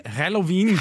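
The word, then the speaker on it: Helloween: Det uttales faktisk Halloween.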